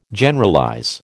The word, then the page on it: Generalize: American English